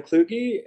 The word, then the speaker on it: Kluge: I have been pronouncing your name wrong the entire time I've known you. – And how have you been pronouncing it? – I've had a lot of variations. – Just Kluge, I never pronounced the E. – Yeah, Kluge. I think Kluge. It's Germanic. Germanic, yeah.